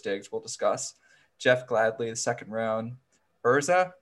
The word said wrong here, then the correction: Ezra: Cleveland, did I say that right? – You know Ezra. how We'll go with Ezra.